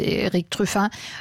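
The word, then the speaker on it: Erik Truffaz: Das ist Eric Truffat aus Frankreich, ein Jazz-Trompeter, beziehungsweise aus der Schweiz kommt er, aber spricht sich eben französisch aus, Eric Truffat.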